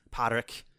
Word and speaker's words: Padraic: And if you pronounce it with a thick Irish accent, it's Padraic.